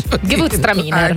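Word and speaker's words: Gewürztraminer: Gewurztraminer. Se non serve che tu faccia proprio la pronuncia originale, Gewurztraminer. – Eccola.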